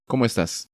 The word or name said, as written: ¿Como estas?